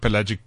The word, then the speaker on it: pelagic: So the albatross that never comes ashore is known as a pelagic.